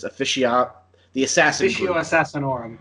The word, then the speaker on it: Officio Assassinorum: You'll see I can't pronounce this, Officio Assassinorum. – Thank you, Assassinorum.